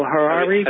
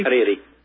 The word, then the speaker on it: Hariri: El Harari? – Hariri.